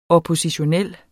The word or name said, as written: oppositionel